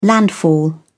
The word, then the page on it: landfall: British English